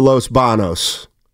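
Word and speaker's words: Los Baños: That's the area code for Los Banos. Banos. – Oh, thank you. You said it right the first time. – Steiny is right. The alternative and in Spanish is Los Banos. There's a tilde above the N, I believe. – It's called an enye, and there's not one when you look at the city sign.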